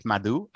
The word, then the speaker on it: Madhu: First name you got right, Madhu.